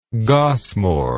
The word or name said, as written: gosmore